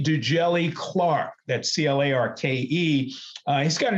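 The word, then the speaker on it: Djèli: Dujelle Clark. And his middle name is spelled D-J-E-L-I. – P. Dujelle Clark.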